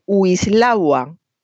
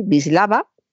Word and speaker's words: Wisława: Por cierto, se empeña mucha gente en decir Wislawa. – No. – Y la W solo la pronunciamos como W cuando corresponde al inglés o a lenguas cercanas al inglés. Si no, como pasa en el alemán, también la pronunciamos con B, así que hay que decir Wisława.